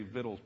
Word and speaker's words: victuals: And that really is pronounced that way. Every time I say victuals, people laugh at that. But you look it up in the dictionary, it's really pronounced.